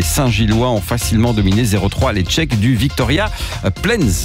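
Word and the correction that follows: Viktoria Plzeň: Je crois même que ça se prononce Victoria Pilsen. Voilà, je pense.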